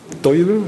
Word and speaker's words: Duivel: Ich zeige Ihnen ein Beispiel eines Tabubruchs am Beispiel von Advokat van de Duivel. Wird das so ausgesprochen? Duivel, ja.